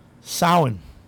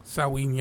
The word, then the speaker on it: Samhain: Or if you want to pronounce it just the way it's Samhain, pronounce it the way it's spelled.